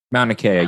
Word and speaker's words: Mauna Kea: He was climbing Mauna Kea. – Mauna Kea, yeah.